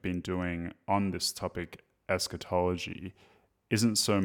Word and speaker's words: eschatology: On your website, you say that you're primarily concerned with eschatology. Is that how it's pronounced? Okay, eschatology, yeah.